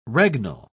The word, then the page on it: regnal: British English